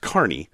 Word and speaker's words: Kearney: In Kearney, Nebraska, which is spelled Kearney, but it's pronounced Kearney, you have driven under this massive arch.